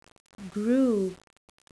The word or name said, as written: grew